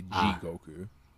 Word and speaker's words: Jigoku: I just need to make it G. G. It's G-Goku. Not gonna be a soft I. It's gonna be a hard I. It's gonna be G Goku.